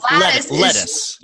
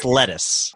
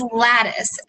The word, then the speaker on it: lattice: So lattice. – You're saying that wrong. It's lettuce. – No, it's lattice. L-A-T-T-I-C-E. It's the basket weave shape.